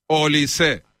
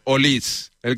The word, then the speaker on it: Olise: es Olise. Según Pablo Polo, Olis. El que controla francés dice que se pronuncia Olis. Aquí en España Olis. Le llamamos Olise, porque nos sale del forro.